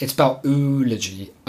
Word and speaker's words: oology: Which is spelled oology, O and then ology.